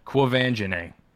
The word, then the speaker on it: Quvenzhané: Quvenzhané. – Quvenzhané. – I'm just saying it because I taught myself how to pronounce it. And I don't want to just pronounce it once. – Quvenzhané.